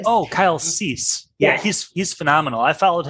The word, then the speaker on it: Cease: It's spelled C-E-A-S-E, I believe. Yes. Kyle Cease.